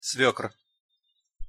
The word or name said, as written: svekr